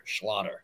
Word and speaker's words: Schlatter: I always say it's like a, like it rhymes with otter, so it kind of sounds like a Jewish otter, Schlatter.